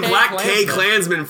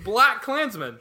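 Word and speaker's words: BlacKkKlansman: You've been saying Black K Klansman for like months. I don't understand. – It's not Sterling K Brown. It's Black Klansman. – He turns to the camera and he goes, "K is silent."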